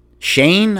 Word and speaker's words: Schoen: They hired Joe Shane, I guess you pronounce the name. S-C-H-O-E-N. Shane. Joe Shane.